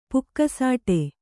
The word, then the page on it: ಪುಕಸಟ್ಟೆ: kannada